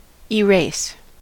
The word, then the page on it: erase: American English